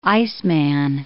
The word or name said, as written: iceman